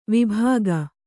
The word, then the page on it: ವೈಭೋಗ: vaibhōga